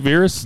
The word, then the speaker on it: Virus: I hope that Virus, that's how you say it, right? Virus? – Yes. – They don't say virus. – No. – It's spelled virus, but yes. They say Virus.